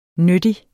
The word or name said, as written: nyttig